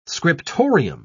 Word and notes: scriptorium: British English

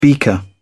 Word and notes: beaker: British English

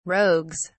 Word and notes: rogues: British English

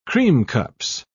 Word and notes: creamcups: British English

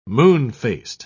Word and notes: moon-faced: British English

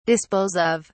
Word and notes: dispose of: British English

